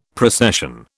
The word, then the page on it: procession: British English